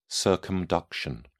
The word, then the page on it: circumduction: British English